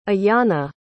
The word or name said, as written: Iyana